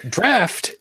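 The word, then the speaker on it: draught: Draft is the proper pronunciation and means straight from the barrel.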